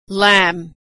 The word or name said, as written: lamb